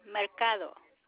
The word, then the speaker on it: Mercado: Mercado. – Can you spell that, please? – M as in Mary, E-R-C-A-D-O, Mercado.